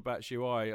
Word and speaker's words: Batshuayi: Is that the official pronunciation? – Yes, Batshuayi? I believe so. Batshuayi. – Batshuayi. All right. – Batshuayi. – Thank you very much. – So I'm told. Well, it rhymes with that.